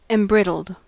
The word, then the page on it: embrittled: American English